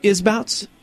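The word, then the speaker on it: Isbouts: Am I saying that right? Isbots? – You're saying it correct. That's absolutely.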